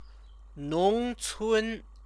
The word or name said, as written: nongcun